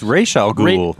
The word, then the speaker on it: Ra's al Ghul: It's Ra's al Ghul.